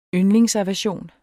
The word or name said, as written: yndlingsaversion